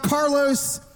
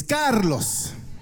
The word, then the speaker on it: Carlos: My name is not Carlos, it's Carlos. So uh, listen, I, I, know what part of the country I'm in. I, I, bet you guys can roll your R's at every single campus here in this room.